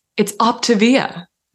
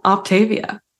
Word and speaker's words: Optavia: So it's Optavia, not Optavia.